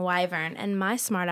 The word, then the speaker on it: wyvern: But it is wyvern because then I looked up the pronunciation, and it is, in fact, wyvern.